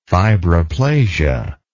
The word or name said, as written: fibroplasia